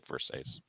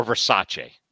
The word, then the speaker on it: Versace: A gift from coaching legend Dick Versace when he was with the Grizzlies. I'm friends with his son, Dave Versace, or Versace, as some people like to say. But really, he pronounces it Versace.